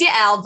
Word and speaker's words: Yael: It's Yael, but I tell this to everyone. I'm like, almost nobody pronounces it correctly the first time. So like, it was close. Some people call it Yael. I'm like, nope.